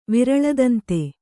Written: ವಿರಳದಂತ

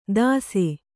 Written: ತಸೆ

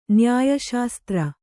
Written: ನ್ಯಾಯಶಾಸ್ತ್ರ

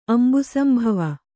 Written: ಅಂಬುಸಂಭವ

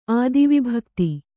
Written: ಆದಿವಿಭಕ್ತಿ

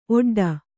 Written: ಒಟ್ಟೊಟ್ಟೆ

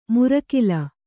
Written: ಮುರಕಿಲ

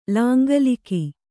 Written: ಲಾಂಗಲಿಕ

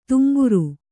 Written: ತುಂಬುರ

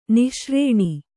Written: ನಿಶಾರಣ

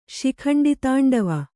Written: ಶಿಖಂಡಿತಾಂಡವ

